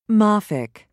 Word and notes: mafic: British English

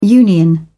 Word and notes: union: British English

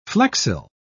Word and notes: flexile: British English

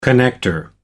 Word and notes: connector: American English

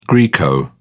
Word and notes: greco: British English

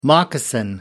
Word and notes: moccasin: American English

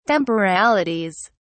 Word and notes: temporalities: British English

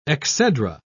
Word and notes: exedra: British English

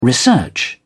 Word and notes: research: British English

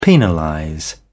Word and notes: penalize: British English

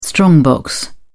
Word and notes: strongbox: British English

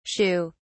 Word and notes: sheugh: British English

